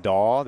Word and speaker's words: Dahl: My last name, Dahl, they would spell D-O-L-L.